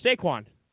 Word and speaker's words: Saquon: Sure, Saquon.